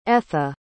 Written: etha

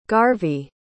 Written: garvey

